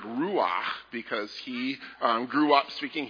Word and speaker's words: ruach: there's a word for spirit and wind that's ruach. But you have to say it like this. Ruach. Yes. Yeah. Get it in the back of your throat.